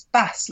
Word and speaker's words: Bass: Please satisfy my curiosity, because although I know I will have gone past the area several times on the train, I am not sure whether I am pronouncing it Bass Rock or Bass Rock. – It's Bass, like the fish.